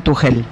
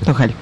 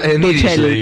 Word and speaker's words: Tuchel: Ayer aprendí, vinieron los chicos de la redacción de Deportes del Destape anoche a Batalla y aprendí que se pronuncia Tuchel. – Ahí está. – Tuchel. – Yo creí que era Tuchel, pero bueno. – Tuchel.